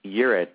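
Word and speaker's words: Ewart: So it's E-W-A-R-T, Yurt.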